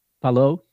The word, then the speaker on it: Palou: Am I saying that right? Or Palou? – Palou. – Palou, thank you.